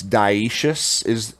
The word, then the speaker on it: dioecious: It's dioecious is the, I believe, the word and how it's pronounced.